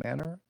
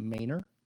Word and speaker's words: Manor: And then once we get past Manor, I have been told I was mispronouncing it previously when I called it Manor. My understanding, it is properly pronounced Manor.